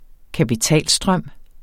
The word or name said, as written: kapitalstrøm